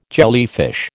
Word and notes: jellyfish: American English

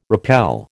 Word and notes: repel: American English

